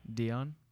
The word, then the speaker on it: Dionne: Dion. – How do I spell that? – D-I-O-N-N-E.